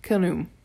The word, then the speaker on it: Khnum: That'd be pronounced Khnum, I suppose. Rhymes with kaboom?